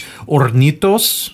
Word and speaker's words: Hornitos: It's Hornitos. – Mm-hmm. – The H is silent in Spanish, by the way. – Okay. – Hornitos spiced honey.